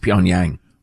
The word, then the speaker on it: Pyongyang: Young people need to stick to their country's, quote, superior language, which is based on a dialect spoken in Puntang. – I believe that's pronounced Pyongyang.